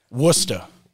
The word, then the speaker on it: Worcester: Listen, I always say when you pronounce it, you got to spell it in your head like W-O-O-S-T-A. – Worcester.